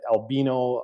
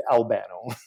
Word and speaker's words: Albano: Albano, Dave Albano. And that is the correct pronunciation of my name, by the way. Some you nailed it, Jenna, but some people totally butcher it. I get Albino, Albino, Albono, all the variations. It is indeed Albano.